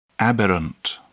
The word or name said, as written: aberrant